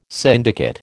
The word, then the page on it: syndicate: American English